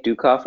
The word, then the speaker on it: Ducoff: Well, so I'm Nick Dukoff on Twitter. My last name is spelled D-U-C-O-F-F, and I have two lists that you can follow where I've been kind of curating folks to follow.